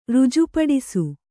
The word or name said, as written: ರುಜುಪಡಿಸು